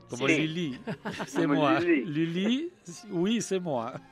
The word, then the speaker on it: Lully: Bueno, y es que con esta música introduzco al compositor de hoy, que es Lully, Jean-Baptiste Lully. – Lully, para mí era Lully. – Lully. Bueno, claro, mal te lo enseñaron.